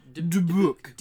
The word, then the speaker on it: Dubuc: He was played by Nolan DeBook. – Nolan... what? – Dubuc, I think. – Dubuc? – I don't know how to pronounce his last name. – Dubuc. – D-U-B-U-C. Nolan Dubuc.